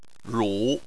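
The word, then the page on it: ru: tone 3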